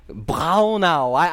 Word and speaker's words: Braunau: And they pronounce it with that Gulch, you know, that German Braunau. I took a long time trying to learn to do that. I don't think I can even do it right now, but that's how they say it.